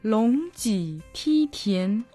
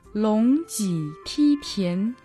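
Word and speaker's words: Лонг Ти Ти Тян (龙脊梯田): Лонг Ти Ти Тян. Лонг произносится во втором тоне, Ти в третьем тоне, Ти в первом, а Тян во втором тоне. Лонг Ти Ти Тян.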